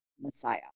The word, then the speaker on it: Messiah: Well, proper way to say it is Messiah.